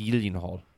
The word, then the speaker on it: Gyllenhaal: Or how it's properly pronounced, Gyllenhaal. – That's true. Swedish.